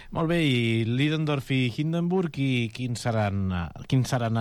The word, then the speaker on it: Ludendorff: Clar, el dia 23 d'agost, Ludendorff i Hindenburg, has pronunciat molt millor tu el nom de la, Ludendorff, no?